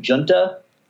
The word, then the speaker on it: Giunta: Blake Giunta, G-I-U-N-T-A.